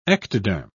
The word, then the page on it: ectoderm: British English